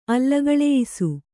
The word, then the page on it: ಅಲ್ಲಗಳೆಯಿಸು: kannada